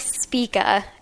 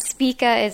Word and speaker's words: Spika: So my name is Allie Spika, and Spika is S-P-I-K-A, which is kind of hard to pronounce.